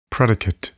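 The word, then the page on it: predicate: noun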